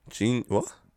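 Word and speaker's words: jeen-yuhs: Genius. It's spelled J-E-E-N and hyphen and then Y-U-H-S.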